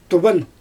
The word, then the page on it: tuban: Xyzyl